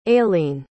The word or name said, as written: ailein